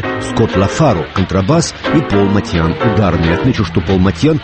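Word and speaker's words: Motian: В первые годы 49 минут джаза я произносил его фамилию как Моушен. Потом великий знаток джаза, живущий нынче в Бостоне, Сабиржан Курмаев, написал мне, что Пол армянских кровей и что его фамилия и звучит по-армянски Матьян.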